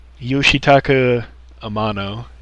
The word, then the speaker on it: Yoshitaka Amano: That vein, we have another question from Budai, which is Yoshitaka Amano.